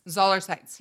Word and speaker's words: Zoller-Seitz: As Matt Zoller-Seitz. – Zoller-Seitz, yeah.